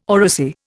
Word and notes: oracy: Indian

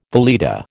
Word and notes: valeta: American English

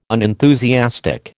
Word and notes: unenthusiastic: American English

